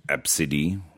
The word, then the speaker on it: ABCDE: The name of the girl was Absidy, spelled as A-B-C-D-E and pronounced as Absidy.